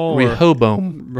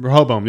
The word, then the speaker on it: Rehoboam: Rehoboam. – Rehoboam, yes, it.